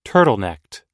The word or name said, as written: turtlenecked